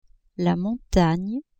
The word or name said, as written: montagne